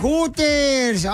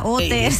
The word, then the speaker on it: Hooters: Bueno, pues sí, porque la H es muda. Hooters.